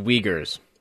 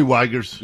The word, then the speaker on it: Uyghurs: It is pronounced Uyghurs, Dory. – What did I say? Did I say Uyghurs? – Yeah.